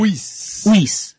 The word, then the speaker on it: Uis: Uis.